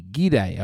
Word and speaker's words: Gide: So André Gide, I was reading about, and G-I-D-E, Gide, I was saying, but André Gide is apparently how it's said, and he was a pederast.